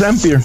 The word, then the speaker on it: Ampere: Les voy a decir rapidito de qué se trata ya viene con la nueva eh, tecnología que se llama Ampere. Así se pronuncia, vea, gringo. O Ampere. – Sí, sí, sí. Ampere. – Ampere. – Es Ampere, perdón.